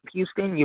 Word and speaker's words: Huston: You can find me on Facebook at Tyrone, T-Y-R-O-N-E, like the song. My last name is Huston, Houston. You pronounce it like the city in Texas, but you spell it different.